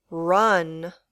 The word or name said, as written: run